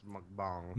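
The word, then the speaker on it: mukbang (먹방): And it's called mukbang. I'm probably pronouncing it incorrectly. – I believe it's pronounced mukbang. – Mukbang. – Mukbang. It's mukbang.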